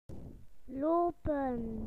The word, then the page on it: to walk: British English